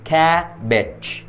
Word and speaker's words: cabbage: Ở đây chúng ta sẽ nhấn âm đầu tiên, k, a, k, đúng không nào? K, kha, và âm còn lại chúng ta phải xuống dấu huyền, mình sẽ đọc là b it bit nặng bit, có cái âm chờ ở phía cuối. Vậy thì từ này chúng ta sẽ đọc là cabbage, cabbage, đánh vần y chang như tiếng Việt.